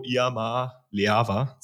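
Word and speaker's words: Iamaleava: Ähm, ich glaube aber tatsächlich an Nico Iama Leava, so wird er ausgesprochen,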